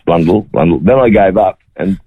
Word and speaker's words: Blundell: So it should rhyme with bundle. And don't ask me why. Any phonetic will tell you that should be Dell at the end, but that's just how we got branded, and it's Blundell is the correct pronunciation.